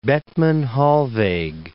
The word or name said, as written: Bethmann-Hollweg